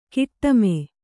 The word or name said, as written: ಕಾಟಿಮೆ